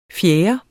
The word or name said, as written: fjerde